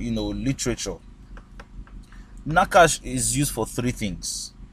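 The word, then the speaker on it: Nakash (נָחָשׁ): Is the word Nakash. Nakash. Nakash is spelled N-A-C-H-A-S-H. All right, that's Nakash.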